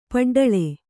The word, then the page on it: ಪಡ್ಡಳಿ: paḍḍaḷi